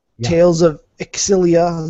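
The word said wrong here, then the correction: Xillia: Zilia, I believe it's actually pronounced, uh, 12 on the 3.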